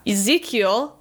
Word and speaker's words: Ezekiel: Ezekiel. I do not know if I'm pronouncing that correctly. – That's correct.